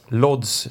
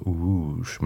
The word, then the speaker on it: Łódź: Lodz, säger vi som bara läser bokstäverna. – Ja, precis. Det är det där som Łódź. Det finns en litet streck över L som gör att man ska uttala det så. Łódź. Men L-O-D-Z stavas det precis.